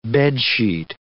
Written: bedsheet

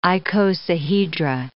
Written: icosahedra

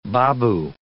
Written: babu

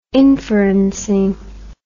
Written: inferencing

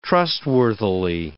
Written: trustworthily

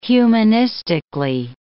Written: humanistically

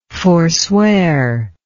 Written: foreswear

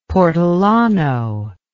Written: portolano

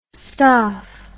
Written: stoph

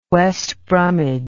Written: west bromwich

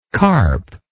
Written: carper

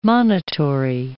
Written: monitory

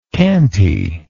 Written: cantiness

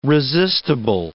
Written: resistible

